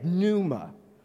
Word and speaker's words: pneuma: You know, the Greek word for spirit is pneuma. Oh, I exaggerated, but, you know, pneuma. You know, it's like PN, pneuma.